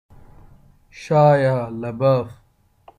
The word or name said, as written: Shia LaBeouf